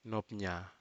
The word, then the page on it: nopnya: Aneityum